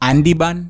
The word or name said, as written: ANDIBAN